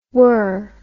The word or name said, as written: wer